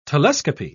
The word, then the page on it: telescopy: British English